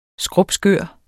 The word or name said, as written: skrupskør